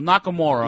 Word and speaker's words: Nakamura: Well, Nakamura. – Well, the U is silent a lot of the times.